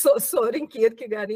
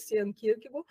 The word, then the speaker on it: Søren Kierkegaard: que é como se fala Søren Kierkegaard em dinamarquês, Søren Kierkegaard,